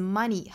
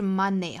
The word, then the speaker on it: money: 包括像 many, money, 那像钱这个词 money, 很多人就会读成 money, 不是 money 哈，应该是 money, 应该是 e 紧音。